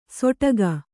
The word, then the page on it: ಸೊಟಕ: soṭaka